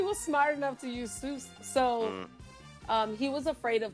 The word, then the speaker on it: Seuss: My nigga, it's pronounced Seuss.